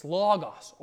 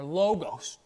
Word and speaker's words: logos: You see, the word, our English, and in the Greek, that's logos or logos, depending on how your professor pronounces it.